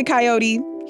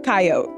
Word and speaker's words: coyote: I say coyote, he says coyote. – Yeah, so that is the number one question I get asked. Either pronunciation is technically correct, so okay. Feel comfortable calling them however you want.